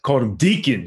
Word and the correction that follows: Dickon: because I'm like, Daikon, maybe Deacon, Dickon.